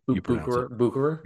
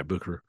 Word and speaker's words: Bucherer: Bucherer. – Yeah. Bucherer.